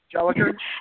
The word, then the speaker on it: Jolicoeur: Jennifer Jolicoeur. Did I say it right? Jolicoeur? – Jolicoeur, yeah.